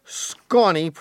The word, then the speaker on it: Sconey: Here's one from Jake Sconey, pronounced like Ronnie, and Chatch.